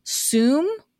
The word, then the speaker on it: Soom: And it's from a company, I believe, pronounced Soom, S-O-O-M. Is that how you would pronounce it, Jason? Soom? – Yes, that is how I would pronounce it.